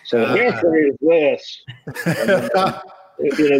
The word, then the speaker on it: Hickory: For whatever reason, we don't pronounce the O in Hickory. It's just Hickory.